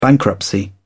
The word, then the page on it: bankruptcy: British English